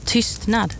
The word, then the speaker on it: tystnad: And the word is tystnad, which means silence. It's spelled T-Y-S-T-N-A-D.